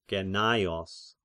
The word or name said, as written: Gennaios